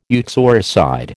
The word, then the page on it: uxoricide: American English